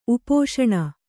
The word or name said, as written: ಉಪೋಷಣ